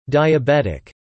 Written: diabetic

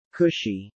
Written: cushy

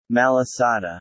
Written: malasada

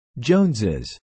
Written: Joneses

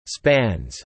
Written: spans

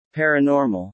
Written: paranormal

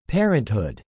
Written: parenthood

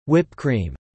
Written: whip cream

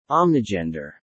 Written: omnigender

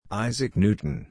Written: Isaac Newton

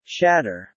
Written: shatter

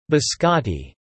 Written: biscotti

